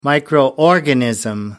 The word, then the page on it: microorganism: American English